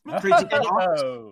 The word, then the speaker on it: Crazy Coqs: Crazy Cox. – It's spelled Crazy Cox, C-O-Q-S.